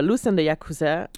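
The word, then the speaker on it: Lous and the Yakuza: Et c'est de Loose and de Yakuza. Est-ce que je prononce correctement? Eh, oui, c'est ça. Loose and de Yakuza.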